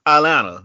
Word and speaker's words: Atlanta: At you trying to pronounce the T in Atlanta. – Atlanta, yeah, Atlanta.